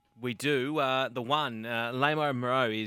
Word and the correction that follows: Les Amaro: is at $3. Actually, I'll start again there. Les Amaro. Is that how we pronounce it, gentlemen? The one? – Les Amaro.